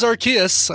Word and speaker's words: Arceus: Oh, it is Arceus. I'm glad. Or Arceus. Arceus. Yeah, it's a hard C.